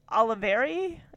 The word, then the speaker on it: Oliveri: And the fly guts spell out Robert Oliveri. Is that how you say his name? It's like Oliver with an I at the end.